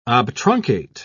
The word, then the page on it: obtruncate: British English